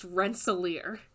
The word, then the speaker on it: Rensselaer: But Ben Kissel, please know, it is Rensselaer. It is pronounced Rensselaer.